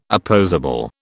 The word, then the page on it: opposable: British English